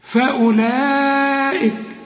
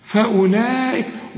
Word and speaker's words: فأولئك: فأولئك، طبعا الواو أنا قلت لك قبل كده كل واو على الخمسة دي يبقى حرف علة لا ينطق، بنكتبه أيوة في القرآن لكن ما ننطقوش. شوف فأولئك، ما تقولش فأول، لا، فأولئك، وده مد واجب بقى عند الوصل وعند الوقف، المد والهمزة في الكلمة واحدة، هم الخاسرون.